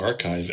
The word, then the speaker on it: arXiv: I'm shocked that ARXIV is pronounced archive and not AR-14.